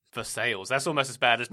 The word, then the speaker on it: Versailles: There is, this is their pronunciation, Versailles, Kentucky.